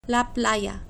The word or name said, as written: la playa